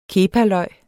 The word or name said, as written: kepaløg